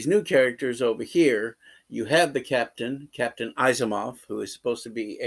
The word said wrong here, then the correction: Asimov: Asimov, thank you.